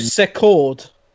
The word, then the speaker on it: Secord: Kent Allard, and Cliff Second. – Cliff Secord.